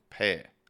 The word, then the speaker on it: Power: They've managed to shorten Power to one syllable, Pair.